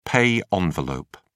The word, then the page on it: pay envelope: British English